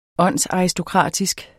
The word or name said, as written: åndsaristokratisk